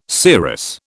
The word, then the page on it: serous: British English